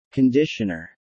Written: conditioner

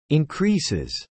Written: increases

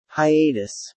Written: hiatus

